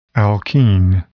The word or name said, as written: alkene